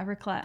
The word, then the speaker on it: raclette: Raclette, is that how you say it? – Yeah, raclette.